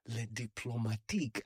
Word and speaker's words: Le Diplomatique: Le Diplomatique. I've often struggled to pronounce it with the right accent. So now that I've heard you say it, I'm like, oh, okay. That's how you say it.